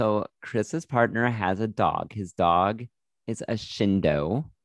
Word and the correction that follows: Jindo: It's a Korean breed of dog. – However, no, Jindo.